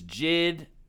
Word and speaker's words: JID: I think we've debated that on here, J.I.D. versus Jid. I've heard him pronounce it Jid, so that's what I'm rolling with.